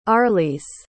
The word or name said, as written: arlys